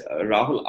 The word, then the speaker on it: Rahul: So just to spell it out a little bit for people who don't know, So my name is spelled as Rahul, R-A-H-U-L,